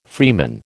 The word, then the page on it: freeman: American English